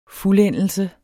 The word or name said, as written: fuldendelse